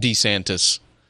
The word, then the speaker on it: DeSantis: I'm going with DeSantis because I think that's the original Italian pronunciation, but anyway. DeSantis. – DeSantis.